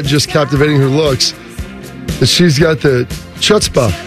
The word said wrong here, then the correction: chutzpah: Oh. Chutzpah. – Sorry, back it up. I apologize for my butchering there of the Hebrew.